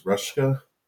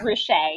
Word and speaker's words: Ruscha: The gentleman, I believe, is it pronounced Ruska? – Ed Ruscha. It's a complicated Ruscha, one, but it's Ruscha, yeah.